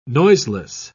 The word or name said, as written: noiseless